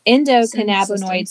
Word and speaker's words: endocannabinoid: Endocannabinoid system.